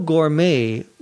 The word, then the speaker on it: gourmet: No gourmet would be bowled over by it, he says. A gourmet, G-O-U-R-M-E-T, it comes from the French, so the last T is not pronounced, is a person who's very knowledgeable about good food.